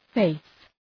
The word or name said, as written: faith